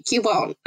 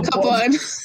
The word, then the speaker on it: Cubone: So Cubone goes cute, Cubone. – I prefer pronunciation of Cup One. Cup One.